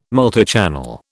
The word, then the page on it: multichannel: British English